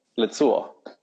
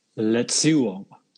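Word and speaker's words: Lazio: But if you think about it as if you say Le, like Les Miserables, like Les. And then think about rather than a Z, a TS, and then it's Yo, Lazzuo. – Lazzuo.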